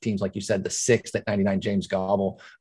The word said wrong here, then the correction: Gable: uh, or Gabal, excuse me. Uh, actually, I think that might even be mispronunciation, but James Gable.